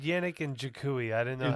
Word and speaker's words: Yannick Ngakoue: All right, so speaking with Shell's pronunciation, when you do your podcast, Mike, his name is Yannick Ngakoue. – Yannick Ngakoue.